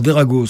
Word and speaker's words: Drago: Alors, Derago, ça s'écrit D.R.A.G.O. Tout simplement parce que, il y a une époque, quand j'ai commencé à vouloir chanter, j'ai cherché un nom de scène. Et donc, dans Derago, il y a mes initiales, donc le D et le O.